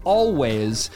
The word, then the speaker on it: Alvvays: They're a Canadian indie pop band called Alvvays. It's spelled weird though, it's A L V V A Y S. They use two V's as a W.